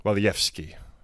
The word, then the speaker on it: Walijewski: servant of God Joseph Walishevsky. Walijewski, sorry. – Mm. Or Walijewski, Walijewski. I'm not sure how they say it in Wisconsin, but mm.